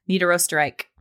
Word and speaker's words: Niederösterreich: Niederösterreich, right? R-I-C-H, Reich. – Reich. – Niederösterreich. – Yes, Niederösterreich.